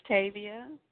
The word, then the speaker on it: Tavia: Hi, this is Tavia.